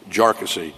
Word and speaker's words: Jarkesy: And by the way, it's pronounced Jarkesy, not a number of other ways that it's been pronounced by many.